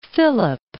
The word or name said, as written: Port Phillip Bay